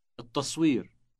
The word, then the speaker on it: التصوير: التصوير التصوير Note the elongated T sound on the beginning.